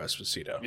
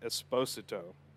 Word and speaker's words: Esposito: More people know him as Gus from Breaking Bad than they know him as Giancarlo Esposito. – Yeah. – So I think that's a good pick. – And it's actually, sorry, I pronounced that wrong. It's pronounced Esposito.